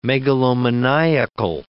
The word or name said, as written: megalomaniacal